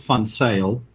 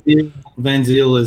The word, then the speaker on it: Van Ziel: In Afrikaans, we would pronounce it Van Sale. – Okay, there we go. It sounds way more official. – Van Ziel is how you would say it in English.